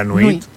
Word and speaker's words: nuit: La nuit.